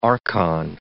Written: archon

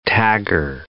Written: tagger

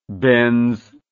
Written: Benz

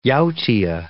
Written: yautia

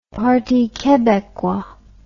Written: Parti Quebecois

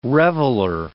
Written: reveler

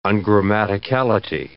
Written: ungrammaticality

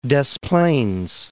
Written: Des Plaines

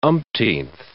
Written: umpteenth

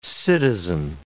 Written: citizen

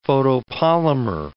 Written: photopolymer

